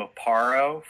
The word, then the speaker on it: Aparo: and I really like Jim Aparo from mm-hmm.